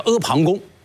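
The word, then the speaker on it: 阿房宫: 北京大学汉语史学科的开创者啊，著名学者王立先生认为这个仿就是旁啊，旁边的旁啊，你看看这读音也很相似。所以阿旁是什么意思呢？就是在一个大山的旁边的一个宫殿啊，叫阿旁宫。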